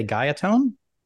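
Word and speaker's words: Guyatone: I don't want to mispronounce the name, but I want to say Gaiatone. – That's right.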